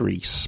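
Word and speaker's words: Re6ce: Now, it's pronounced Reese, but it's spelled R-E, number 6-C-E.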